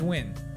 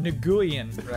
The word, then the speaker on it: Nguyen: But there's one particular last name that we got to set the record straight on this. And that is Wynn. – Right. – Which Brandon pronounced Nguyen.